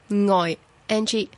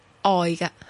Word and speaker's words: 爱: 亦都有少少可以斟酌嘅，喺呢首歌咧嘅爱字咧吓，歌手咧全部咧都系唱咗做有 NG 声母嘅，咁而事实上爱字咧吓两个读音都得，一系可以读做爱 NG 吓，亦都咧可以读做爱嘅吓，即系话零声母。